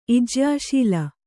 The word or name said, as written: ಇಜ್ಯಾಶೀಲ